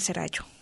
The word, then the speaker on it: Serrallo: Ahí se pronuncia, está, bueno, mal pronunciado, es Idomeneo, rey de Creta, y el rapto del Serrallo.